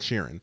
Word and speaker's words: Sheeran: Everybody keeps saying his name differently, but is that how you say it? – That's his actual name pronunciation, Ed Sheeran.